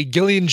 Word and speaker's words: Gillian: Hey, Gillian Jacobs pronounces it with a hard G from Community.